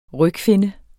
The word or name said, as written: RYGFINNER